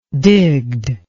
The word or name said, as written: digged